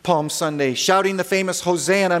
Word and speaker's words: Hosanna: Hosanna is the Greek rendering, the Greek pronunciation of the original Hebrew, Hoshi'ana, Hoshi'ana, save us, we pray, O Lord, which in Greek is Hosanna, and we retain it. Hosanna.